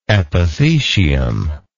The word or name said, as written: epithecium